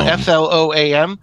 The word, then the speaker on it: Floam: Is it called floam? That's how I remember it. Floam. F L O A M. – Floam. Is correct.